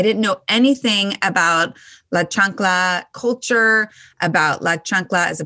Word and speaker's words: chancla: Chancla. I said that right. – Yes. – Okay. – You got it.